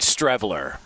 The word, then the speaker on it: Strevler: I'm seeing Strevler. Chris. – Yeah, Strevler. Who wouldn't know that? Not Stevler. – The R is silent. – It's not Stevler.